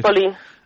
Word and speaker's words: Ladispoli: Ladispoli.